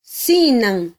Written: sinan

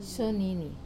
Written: sonini

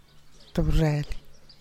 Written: tub reel

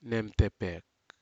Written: nemtepek

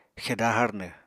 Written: xeda harnw

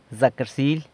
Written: Zac rsily!

